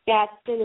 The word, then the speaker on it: Gadsden: And my name is spelled E-I-L-E-E-N, Gadsden is G-A-D-S-D-E-N, and I'm also on Twitter.